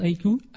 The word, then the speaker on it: HKU: Ça s'appelle Haiku, ça se prononce Haiku. – Haiku. – Ça s'écrit H-K-U.